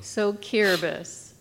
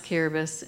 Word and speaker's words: Kiribati: The Samoan trip, which I have a video I'll show next, but always loves talking about the trip to Kiribati. – So Kiribati. It's spelled Kiribati, but yes, it's pronounced Kiribati, and it wasn't even on our list to go there.